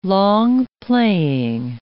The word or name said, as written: long-playing